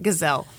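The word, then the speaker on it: Gisele: Gazelle.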